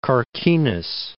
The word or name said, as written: Carquinez Strait